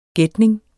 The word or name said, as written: gætning